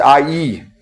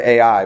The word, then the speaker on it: Ai: It's pronounced IE, which is weird, but that's how it's pronounced. Jericho to IE. I've always said it AI, but it, which is what it says, but it's IE, which is near Bethaven, east of Bethel, and said to them, Go up and spy out the land.